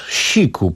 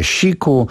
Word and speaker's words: SIIQ: Siku, proszę Państwa, nazwa kontrowersyjna, pisze się S-I-I-Q, czyta się Siku, choć parę osób, tak żeby po prostu nie było tutaj kontrowersji, jakoś to próbowało inaczej spellingować, jak to mówią Anglicy, ale nazwa brzmi po prostu Siku, a utwór nosi tytuł Ego.